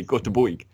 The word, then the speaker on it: Göteborg: I think they really, like, if you're from Gothenburg, you say Göteborg. You really pronounce it totally out of the English mindset. Like, totally different language.